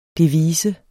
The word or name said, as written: devise